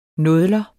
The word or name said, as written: nådler